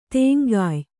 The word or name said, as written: ತೆಂಗಾಯ್